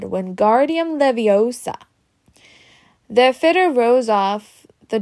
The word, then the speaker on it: Wingardium Leviosa: Wingardium Leviosa, he shouted, waving his long arms about with a windmill. You're saying it wrong, Harry heard Hermione snatch. It's Wingardium Leviosa. Make the gar good and long.